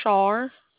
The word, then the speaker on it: Char: It's Char. – How do you spell that? – C-H-A-R.